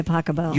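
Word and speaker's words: Pachelbel: Canon D by Pasquale. – By Pacabell. – You pronounce it Pasquale? – No. That's better than.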